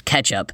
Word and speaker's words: kecap: And others think that it could come from the Indonesian word for fish and soy sauce, kecap, K-E-C-A-P, actually pronounced ketchup.